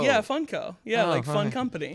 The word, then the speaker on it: Funko: In my mind, I pronounce it Funko, like a funk band.